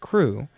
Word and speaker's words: CHRU: Before we get to that, though, WXYC would like you to know that the Carolina Harm Reduction Union, known as CRU, spelled C-H-R-U, but pronounced CRU, is a student-led program that provides life-saving harm reduction resources to the public of Chapel Hill.